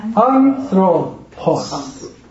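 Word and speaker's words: un throw pos (ἄνθρωπος): 叫 throw，然后 pos， pos， pos，所以它的，所以怎么念呢？第一个是 un throw pos，那重音是在最前面一个，所以叫 un throw pos， un throw pos。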